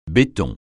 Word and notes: béton: francais

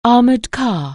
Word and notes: armoured car: anglais

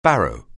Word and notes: barrow: anglais

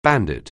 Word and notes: bandit: anglais

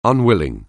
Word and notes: unwilling: anglais

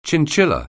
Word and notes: chinchilla: anglais

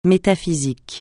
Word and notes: métaphysique: francais

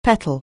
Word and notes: petal: anglais

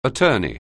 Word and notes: attorney: anglais

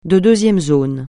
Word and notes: de deuxième zone: francais

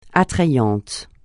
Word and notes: attrayant: francais

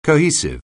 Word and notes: cohesive: anglais

